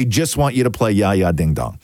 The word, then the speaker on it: Jaja: It's Ya Ya Ding Dong if you want to look it up. And Ya Ya is spelled J-A-J-A, by the way.